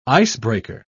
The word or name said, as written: icebreaker